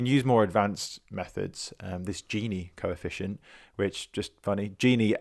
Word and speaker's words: Gini: Gini as in Wijnaldum, not lamp.